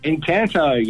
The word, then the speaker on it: Encanto: Encanto, yes.